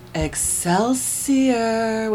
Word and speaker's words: Excelsior: Excelsior. Wait, did I say it wrong? – No, that's right. – No. Okay, hold on. Excelsior.